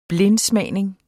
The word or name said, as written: blindsmagning